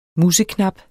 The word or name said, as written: museknap